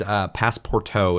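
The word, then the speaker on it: Passpartout: So I'll definitely recommend Passporto. It's spelled PASSPORTOUT. In case you're curious, because it's a name.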